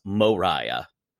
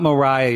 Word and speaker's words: Moriah: The star is, of course, Moriah. And I call her Moriah because her name is spelled Moriah. – That's not Mariah, y'all. That's Moriah.